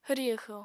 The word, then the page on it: hwri-hwxo: apatani